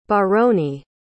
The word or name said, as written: baroni